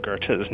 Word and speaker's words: Goethe: But what did they do for the Gold Coast Street, some people call Goethe, named after a German poet? – We really just went back to what the generally accepted pronunciation of Johann Goethe's name is. – Goethe.